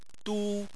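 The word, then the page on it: du: tone 1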